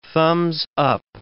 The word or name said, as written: thumbs-up